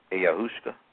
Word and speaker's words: ayahuasca: But when we deal with the issue of time, you've heard of the South American Amazonian shamans drinking a drink called ayahuasca. A-Y-A-H-U-A-S-C-A. Ayahuasca.